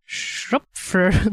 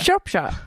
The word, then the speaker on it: Shropshire: S-H-R-O-P-S-H-I-R-E. – Är den, är den, är den ort? Ja. Shropfire?